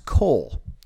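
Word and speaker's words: coal (קול): And it's Strong's Concordance 6963, and it's pronounced coal. Kind of like you'd say coleslaw.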